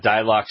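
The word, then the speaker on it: Dylox: So Dilox from BioAdvance, you can find it at a lot of different garden centers.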